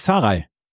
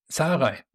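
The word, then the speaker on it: Zahraj: Du kannst mir mal helfen, wie er ausgesprochen wird, weil wirklich original von der Challenger Tour hier rübergekommen. Sarraj oder wie heißt er? Unser deutscher Spieler. – Patrick Sarraj. – Sarraj, okay.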